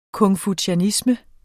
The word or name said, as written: konfucianisme